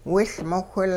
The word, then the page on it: wiLmowhil: Hupa